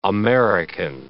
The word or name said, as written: American